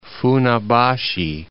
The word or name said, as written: Funabashi